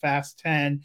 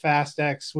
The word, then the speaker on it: Fast X: It should be pronounced Fast 10 and not Fast X,